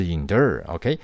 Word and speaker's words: endure: 所以 during endure 哦，注意那个发音是 endure，OK？